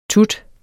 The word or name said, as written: tut